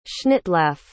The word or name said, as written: schnittlaugh